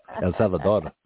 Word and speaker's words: El Salvador: It's also very Salvador. – Similar. Mm-hmm. El Salvador. Okay, I'll work on my pronunciation. Mm-hmm. Give me a chance. El Salvador.